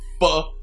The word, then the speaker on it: pho: I said pho. – Like the soup. – Like the soup. Yeah, like the Vietnamese soup. – It's pronounced pho.